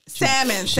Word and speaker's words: salmon: I mean, my blackened salmon. – Did you just pronounce the L on that? – Salmon. Okay, salmon.